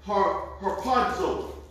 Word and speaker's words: harpazo: So let's look at harpazo. Everybody with me? – Amen. – You see the definition? You see how to pronounce it? Phonetic spelling? – Yeah. – That's how you pronounce it. Harpazo.